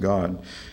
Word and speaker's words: Gad: Gad, actually pronounced God, and Asher.